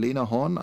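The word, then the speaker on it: Lena Horne: Das eine ist halt ähm, eine relativ kleine Rolle, aber doch wichtige, die, die gute Fee Glinda äh, von, wie wird sie ausgesprochen, Lena Horn einfach, die als, als, die als Sängerin berühmt ist und dann Richard mhm.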